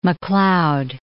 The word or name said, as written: Macleod